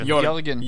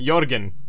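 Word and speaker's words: Jørgen: It's pronounced Jorgen. – Jorgen, my bad.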